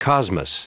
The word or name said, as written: cosmos